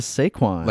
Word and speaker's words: Saquon: You said San Quan instead of Saquon.